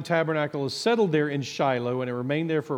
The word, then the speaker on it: Shiloh: In Israel, it's pronounced Shiloh, and so I may jump back and forth at times, but I'll try to stick with East Texas, Shiloh.